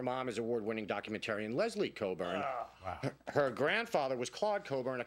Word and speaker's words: Cockburn: Her birth name was Olivia Coburn, that's spelled Cockburn.